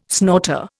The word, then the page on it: snorter: Indian